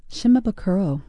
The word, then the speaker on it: Shimabukuro: Shimabukuro.